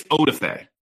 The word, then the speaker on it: Odafe: how do you say that? Adolfo Owe? – I think it's Odafe. – Odafe Owe. – Yeah, I think it is.